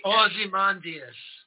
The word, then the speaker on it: Ozymandias: In fact, the, the cover for the book, we have um, Os- Osmond, Os- now I, King Osmondi, how do I say his name? Osmondius. Osmondius, yes, exactly. – Ozymandias. – There you go.